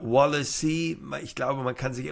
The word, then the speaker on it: Wallace: Bei Sky wird er jetzt immer Wallacy genannt von den Moderatoren. Jens Todt, unser Sportchef, nennt ihn Wallace. Also der müsste es ja eigentlich wissen.